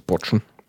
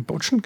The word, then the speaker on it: Patschen: Heißen bei uns Botschen. – Das ist bei uns die Botschen, genau, der Patschen geschrieben, aber Botschen ausgesprochen.